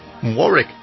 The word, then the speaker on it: Warwick: Voice of Dionne Warwick, or as we would say in English, Warwick is how we do have a town that's called Warwick, which is spelt exactly the same way.